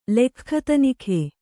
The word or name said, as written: ಲೆಖ್ಖತನಿಖೆ